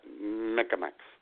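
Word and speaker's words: Mi'kmaq: And it has to be pronounced like that, Micmacs. So. – It's M-M-M-M-M.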